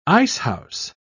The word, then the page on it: icehouse: British English